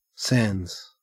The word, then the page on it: sans: Australian English